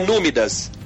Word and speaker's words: númidas: No médios? Não, é númidas.